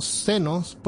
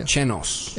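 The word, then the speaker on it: Xenos: Entre ellos están los sinos, que sería, que sería pronunciado como senos, porque es como de xenofobia. O como chenos.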